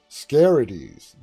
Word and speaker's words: scarities: Never fear, scarities, that's how it's pronounced, scarities.